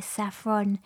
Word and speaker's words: Saphron: Stuff, such a powerful sound, Consent featuring Becca by Saffron.